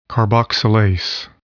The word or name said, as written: carboxylase